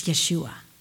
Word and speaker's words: Yeshua: The Hebrew word where it comes from is spelled Y-S-U-A. Would you like to know the pronunciation of that? It's Yeshua. Yes, you heard that correctly.